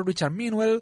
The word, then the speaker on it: Richard Manuel: Dicho esto, hablamos un poquito levemente de The Band, de este megagrupo compuesto por Rick Danko, Garth Hudson, Richard Manuel, es Manuel se escribe pero es americano, Richard Manuel, Robbie Robertson y Levon Helm, incluso unos músicos extraordinarios y además en un grupo en el que no había líder.